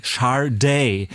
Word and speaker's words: Sade: Wo meine, es spricht sich übrigens also SH und dann Sade, ja. Leute. Also damit ihr wisst, wie das ausgesprochen wird.